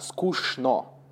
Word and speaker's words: скучно: И я помню, что нам на уроке русского еще в классе там типа в восьмом сказали, что правильно говорить, произносить а, скучно. Да, мы пишем чн, но это правописание, а произносить правильно шн, скучно.